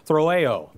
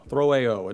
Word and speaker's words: throeo: The word troubled in the Greek here is throeo, throeo. It's spelled T-H-R-O-E-O.